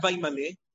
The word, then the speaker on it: Vayimale: No, I'm just trying to accentuate that it's a chirik as opposed to a shva, shvanach. Vayimale haman as opposed to vayimale.